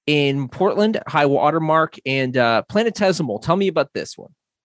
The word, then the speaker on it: planetesimal: Planetesimal. I believe I said that right. Planetesimal.